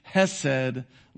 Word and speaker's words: Chesed: And it's spelled C-H-E-S-E-D in Hebrew, but it's pronounced Hesed love. You really got to roll your throat with the H, but I can't really do it.